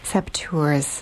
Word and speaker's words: Saboteurs: And am I pronouncing that right? I've been wanting to ask you that all show. So, Sabotageurs. Am I saying that correctly or not? – Saboteurs. – Yeah. Thank you. – It's a hard word because it's kind of a French word. Mm-hmm. It comes from the word sabotage.